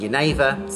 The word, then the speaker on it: genever: Dutch word for juniper being genever, spelt with a G. We found that really hard to say, so we didn't call it genever like they do. We called it Geneva. We found that really hard to say when we were drunk, so we called it gin.